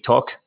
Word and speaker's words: talk: So I only have to correct you one thing though, is that when you say talk, you don't say talk, you say talk. So you don't pronounce the L.